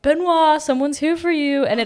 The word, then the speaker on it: Benoît Blanc: But how do you say Benoît? – Benoît Blanc.